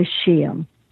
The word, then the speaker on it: Scheele: And the name of it is Yoga Nidra with Linda Scheele. And my last name is spelled S-C-H-E-E-L-E.